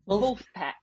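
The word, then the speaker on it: Vulfpeck: Vulpec. – Vulpec. – Yeah, it's V-U-L-F-P-E-C-K, Vulpec.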